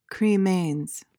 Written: cremains

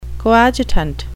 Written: coadjutant

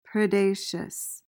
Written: predaceous

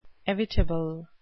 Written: evitable

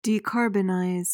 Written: decarbonize

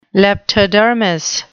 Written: leptodermous

